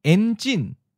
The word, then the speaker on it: enjin: Enjin. En is in the ninth tone. Enjin is in the second tone. High falling.